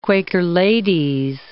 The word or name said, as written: quaker-ladies